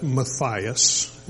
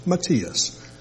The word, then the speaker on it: Matthias: I know that most people pronounce that Matthias, and if you prefer that pronunciation, please have at it. I don't have any objection to it. The correct pronunciation is Matthias, but do what you want.